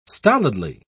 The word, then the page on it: stolidly: British English